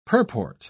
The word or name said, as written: purport